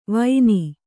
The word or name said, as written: ವೈನಿ